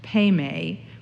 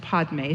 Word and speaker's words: padme: And so the two in between, Mani and Padme, which can be also pronounced Padme, so those of you who are wondering about whether it is Padme or Padme, you are both right. Padme is the Sanskrit pronunciation, and Padme is the Tibetan pronunciation.